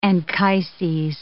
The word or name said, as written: Anchises